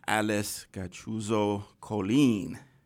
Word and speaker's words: Gachuzo-Colin: And we have a very, very, very special guest with us this afternoon, the wonderful Miss Alice Gachuzo-Colin. I said it right. – You got it.